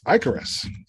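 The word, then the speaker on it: ichorous: It's Icarus. It's pronounced Icarus.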